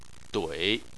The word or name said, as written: dui3